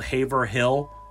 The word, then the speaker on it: Haverhill: And yes, it's spelled Haverhill, but this is New England, so we say Haverhill.